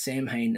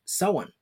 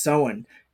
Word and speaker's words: Samhain: Now, funny thing I actually found out, I went reading about it, I kept reading it in my brain as Samhain as well, but it's actually pronounced Samhain because it's the old Gaelic word.